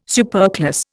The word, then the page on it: superclass: Indian